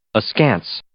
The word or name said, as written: askance